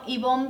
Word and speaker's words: Ivonne: Sí, bueno, Ivonne Vela, mi nombre se escribe con doble N y con E al final.